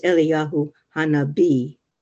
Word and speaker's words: Eliyahu Hanabi: For the B'nai Israel Jewish community in India, they treat Elijah, for them, he is Eliyahu Hanabi, pronounced with a B, with special veneration.